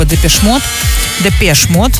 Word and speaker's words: Depeche Mode: И он как раз, он основатель группы Depeche Mode, Андрей все время поправляет нас с этим ударением, но я уже научилась.